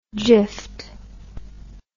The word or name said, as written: jiffed